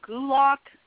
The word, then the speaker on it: Gulak: Is it Drew Gulak or Gulak? – Here's where it gets interesting. It's Gulak is a name, but a submission hold is a Gulak. That's why people pronounce it both ways.